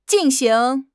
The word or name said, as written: jin4 xing2